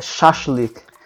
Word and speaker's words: shashlik: Deixa eu ver. É kebab. Nossa, é shashlik. Shashlik. Shashlik, é. – é. Quase que foi.